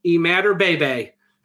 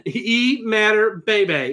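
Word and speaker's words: Imatorbhebhe: Josh, obviously, and if there's any fans of, let's just say, NXT, because this is exactly how it's said, Imatorbebe. – Wow. – Adam Cole. Adam Cole's favorite player. Imatorbebe is how it's said. – Imatorbebe. That's it. Once again, wow. A bunch of highlights of Illinois games from the Big Ten Network. Heard multiple announcers, and that's how it is.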